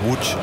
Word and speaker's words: Łódź: De L met zo'n schuin streepje erdoor. Die bestaat in het Nederlands niet, die letter. Maar ja, dat spreek je uit als een V. – Oké. Okay. – Dus, ja, en dan... dan wordt het niet Watts, maar het wordt dus Łódź. Ongeveer.